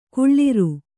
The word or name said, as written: ಕುಳ್ಳಿರು